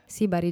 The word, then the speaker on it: Sibari: Sibari, giusto? Va bene come sì, pronuncia? – Sì, sì, sì.